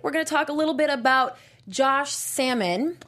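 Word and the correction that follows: Samman: Saman. – I even wrote that out phonetically.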